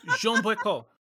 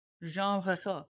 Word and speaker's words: Jeanne Brecourt: Jeanne Brecourt. – Yeah. – There you go. – Yeah, that was right. – Jeanne Brecourt. – Jeanne. – We're gonna. – Because there's an E at the end, so you pronounce the N.